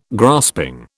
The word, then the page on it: grasping: British English